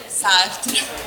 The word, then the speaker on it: Sartre: Jean-Paul Sartre, ragazzi. – Però l'hai pronunciato malissimo, Raffi, te lo dico, eh. Dillo tu, dillo tu. No, dillo tu, almeno la pronuncia giusta. Jean-Paul Sartre.